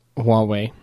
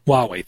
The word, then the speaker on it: Huawei: I can never pronounce this one phone. Huawei. – It's Huawei. – Huawei, thank you.